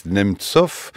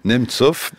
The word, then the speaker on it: Nemtsov: Boris Nemtsov. Je ne sais pas si je mets l'accent tonique comme il faut exactement, Marie Mendras. – Parfait. – Dites-le. Nemtsov.